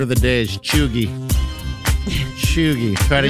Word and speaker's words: cheugy: Well, it's spelled C-H-E-U-G-Y, and it's pronounced chuggy.